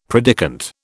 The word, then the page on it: predikant: British English